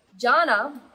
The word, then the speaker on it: Jana: Anyway, Jana, the word Jana with J-H-A-N, which is literally kind of how you pronounce my name, the J-H-A-N-A, is the jhanas are the Buddhism word for the state of consciousness, state of awareness.